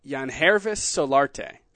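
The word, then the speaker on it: Yangervis Solarte: I think I know how to pronounce this one. Yanhervis Solarte. – Bingo.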